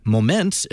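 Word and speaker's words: Momence: He's Pastor David Jewell from Our Savior Lutheran Church in Moments, Illinois. I think I said that right this time. – You got it right.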